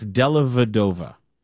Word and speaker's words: Dellavedova: You're missing a syllable there. It's Dellavedova.